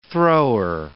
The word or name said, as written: thrower